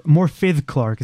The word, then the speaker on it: Morfydd Clark: Morfydd Clark is how you pronounce it, because I think her name is Welsh.